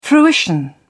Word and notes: fruition: British English

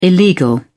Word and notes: illegal: British English

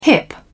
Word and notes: hip: British English

